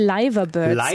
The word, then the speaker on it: Liverbirds: Ja, die, ich glaube, Liverbirds werden sie tatsächlich ausgesprochen.